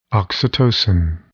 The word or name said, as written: oxytocin